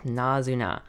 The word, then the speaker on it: Nazuna: Did I say that right? Yep, Nazuna.